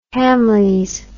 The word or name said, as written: hamleys